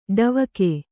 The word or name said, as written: ಡವಿಗೆ